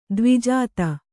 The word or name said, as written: ದ್ವಿಜಾತ